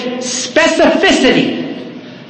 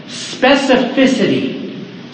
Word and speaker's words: specificity: Pray with specificity. Specificity. It's the word specific. And then, and add the itty at the end. Specificity. That's how it's pronounced.